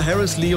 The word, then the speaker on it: Harrislee: Ich sah nur Harris Lee und dachte, das wird natürlich englisch ausgesprochen. Achtung, immer noch Falschfahrer auf der 7 Flensburg-Hamburg zwischen Grenzübergang Ellund und Flensburg-Harrislee.